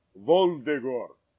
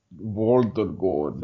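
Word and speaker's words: Voldigoad: He's Voldegord. – Voldegord. – Say it like a vampire.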